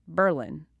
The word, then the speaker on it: Berlin: while it is spelled like the German capital, it's not pronounced Berlin. It's pronounced Berlin. You know, emphasis on the first syllable. It's very, very subtle. Berlin, not Berlin.